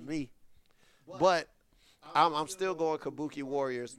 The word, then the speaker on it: Kabuki: How you pronounce it? – Kabuki.